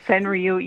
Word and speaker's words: Senryu: How do you say Senryu? – Senryu? Is that how you say it? – Yeah, that's how I say it. Senryu.